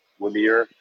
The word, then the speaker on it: Ymir: He's the CSO, Chief Scientific Officer at Wymir Genomics. It's spelled Y-M-I-R.